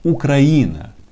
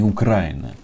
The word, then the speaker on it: Украина: А почему правильно говорить «украинцы»? а не украинцы. Потому что есть государство Украина, а не Украина.